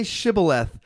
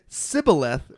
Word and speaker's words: Shibboleth: It says, "All right, say Shibboleth." And if he said Sibboleth without the H.